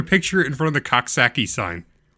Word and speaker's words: Coxsackie: Cockacackie. Cockacackie. – Coxsackie. Hold on. You don't pronounce the X.